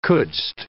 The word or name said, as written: couldst